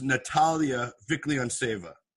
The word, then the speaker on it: Natalia Vikhlyantseva: That is Natalia Vikhlyantseva. Yes. Do I, do I pronounce that perfectly, correct? – Perfect, yes.